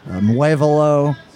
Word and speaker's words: Muévelo: She co-leads the Latin big band, Muévelo. Am I saying that correctly? – Mm-hmm. – Close enough. All right.